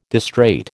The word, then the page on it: distrait: American English